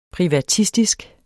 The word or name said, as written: privatistisk